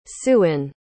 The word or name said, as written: siouan